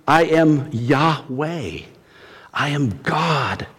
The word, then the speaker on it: Yahweh: Secondly, Jehovah is a variation of the more properly pronounced Hebrew name for God, Yahweh.